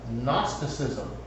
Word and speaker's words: Gnosticism: really dangerous false teaching that we now call Gnosticism. This is from the Greek word for knowledge. You can even see, well, it's spelled with a G, but it's like we spell knowledge with a K, kno. So